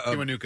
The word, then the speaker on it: Kiwanuka: Kiwanuka is how you say his Kiwanuka.